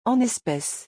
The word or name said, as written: en especes